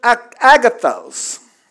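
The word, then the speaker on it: Agathos (ἀγαθός): The word good here is the Greek word agathos. Agathos, and it's spelled A-G-A-T-H-O-S. Agathos.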